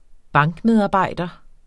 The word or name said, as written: bankmedarbejder